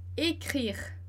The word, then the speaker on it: écrire: Então, été, passé, écrire, écrire. E veja que esse último e, ele não tem acento, então ele não é pronunciado. Então, écrire, acaba no R.